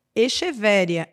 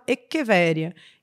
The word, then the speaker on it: Echeveria: Por exemplo, o vendedor na loja vai chamar uma determinada planta de Echevéria, Echevéria. E vai ter gente que vai achar, inclusive, que escreve com X. Só que o nome é, na verdade, pronunciado Echeveria. E aí, eu não vou falar Echeveria para os meus alunos, eu vou falar Echevéria porque é como ele vai encontrar.